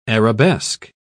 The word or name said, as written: arabesque